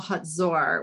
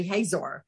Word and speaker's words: Hazor: And so one day we were in Tel HaZor, which, a lot of us, when we read it in the Bible, we say Hazor, but it's pronounced HaZor.